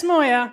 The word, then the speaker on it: Moyer: Jess Moyer, rhymes with foyer, was part of the first all-female team to make the Australasian debating grand final.